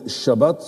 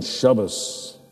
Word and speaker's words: Shabbos: Ze zeggen ook niet Shabbat, ze zeggen Shabbos. De T wordt als een S uitgesproken, dat is een van de verschillen.